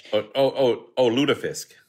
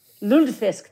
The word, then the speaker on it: lutefisk: Lutefisk. I can never pronounce it, the fish. – Lutefisk. – Lutefisk, thank you very much.